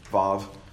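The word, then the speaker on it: Waw: Even the section of the letter that we would call, it's, if you look in your Bibles, it's W-A-W, it's pronounced Wah or Vav, is the other way of pronouncing that.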